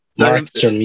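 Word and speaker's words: Markström: Markstrom, yes.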